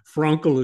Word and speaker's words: Frankl: So Frankl is, I think, the way it is pronounced.